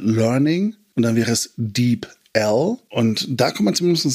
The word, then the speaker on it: DeepL: Ich glaube, DeepL. Ich habe früher immer DeepL gesagt, und irgendwann meinte jemand DeepL. Gut, ja.